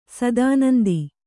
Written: ಶತಾನಂದ

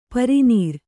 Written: ಪರನಾರಿ

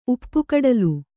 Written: ಉಪ್ಪುಕಡಲು

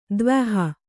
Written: ದ್ವ್ಯಹ

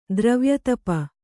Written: ದ್ರವ್ಯತಪ

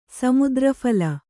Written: ಸಮುದ್ರಫಲ

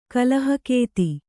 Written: ಕಲಹಗತಿ

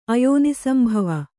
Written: ಅಯೋನಿಸಂಭವ

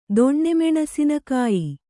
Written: ದೊಣ್ಣೆಮೆಣಸಿನಕಾಯಿ

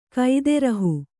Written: ಕೈದೆರಹು

